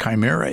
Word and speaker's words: Chimera: And I have always had trouble with the pronunciation of that word, the mythological monster or whatever, which I've heard it pronounced, I don't know, a lot of different ways. And the way that is the predominant one, because I actually checked it out recently on Google because I couldn't stand it anymore, and Chimera is the pronunciation that is preferred, although in fact Google offers several other alternatives.